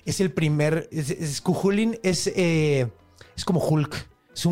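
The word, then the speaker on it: Cú Chulainn: Pero sí, se pronuncia Cuchulín.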